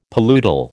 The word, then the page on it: paludal: American English